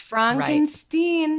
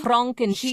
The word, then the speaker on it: Frankenstein: I explained the first sequence, but then we meet Victor Frankenstein, who it's pronounced Frankenstein. – Right. Frankenstein. – He is not a Frankenstein.